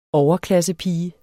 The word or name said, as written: overklassepige